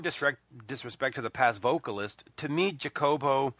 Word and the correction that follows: Jacopo: and I hope that's how you say his name. – To go back to our previous question, I shall pronounce his name for you. His name is Jacopo. – Jacopo.